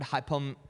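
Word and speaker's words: hypomone: This word steadfastness, you might just write it down, is the word hypomene, which you just spell it like this. H-Y-P-O, M as in mom, O-N-E.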